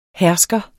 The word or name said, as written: hersker